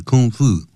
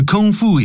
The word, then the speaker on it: kung fu: kung fu actor，这个 kung fu 也可以念成 kung fu 啊，两个念法都可以， kung in kung fu 或者是 kung fu 这样子。好，D by known be known by something，